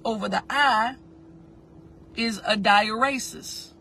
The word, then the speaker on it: dieresis: Diuresis. And that is spelled D-I-E-R-E-S-I-S. Because remember, it's Latin and Greek. So the E is like an A. Diuresis. D-I-E-R-E-S-I-S.